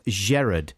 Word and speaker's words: Gerard: This is from Gerard with a soft G, not a hard G.